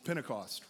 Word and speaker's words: Pentecost: It starts with a P and it rhymes with Rent-a-Cost. Pentecost, right.